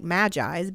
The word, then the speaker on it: magis: But the concept is a word called magis, and it's spelled M-A-G-I-S, so spelled like magis, but pronounced magis.